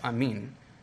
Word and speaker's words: amen: The Greek word for truly is amen, or if you pronounce it correctly like the Greeks, it's amen, which is how the people in those languages would say it.